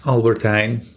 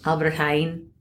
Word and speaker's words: Albert Heijn: So we were visiting your family in Amsterdam, and when I collected these bags, and I got them at Albert Heijn. Wait, how do you pronounce it? – Albert Heijn. – Albert Heijn.